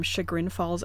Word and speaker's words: Chagrin Falls: So, the main character is named Bronwyn Crewse, and her family is from Chagrin Falls, Ohio.